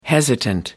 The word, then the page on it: hesitant: American English